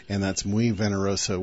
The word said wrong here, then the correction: venenoso: which means very venomous, or something like that in Spanish. Venenoso. Yeah, okay.